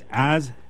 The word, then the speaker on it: Azerbaijan: It's not misspelled. It's just mispronounced. – Mispronounced. – Yes. – Mispronounced, yeah. Because I tried to write it down, but I think I misspelled it anyway. – It's Azerbaijan.